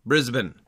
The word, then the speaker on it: Brisbane: Tell me how you pronounce the name of that city. – Brisbane. – There we go. – Yep, that's right. There's no Bane. There's no Bane that we have to worry about.